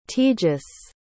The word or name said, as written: tejas